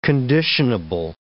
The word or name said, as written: conditionable